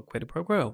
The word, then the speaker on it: quid pro quo: And then she gives him the offer, and then he's like, okay, well, quid pro quo, we're going to do this. Mm-hmm. Still didn't say it right. – No, you got it that time.